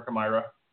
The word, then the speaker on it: Chimaira: Anytime I've experienced the Wall of Death, and I feel like it was Chimaira, where they were, if I pronounced that right, what's the actual pronunciation for Chimaira? – Chimaira. – Chimaira. Okay, I was close. So Chimaira was touring,